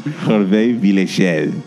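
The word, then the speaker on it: Hervé Villechaize: Hervé Villechel.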